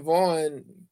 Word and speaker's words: Vaughn: Then again, there's Ethan Vaughn, that's spelled V-A-U-G-H-N.